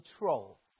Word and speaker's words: treow: Also, if we look at the English word tree, its old English form is written as this, T-R-E-O-W, pronounced as troll.